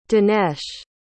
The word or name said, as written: dhanesh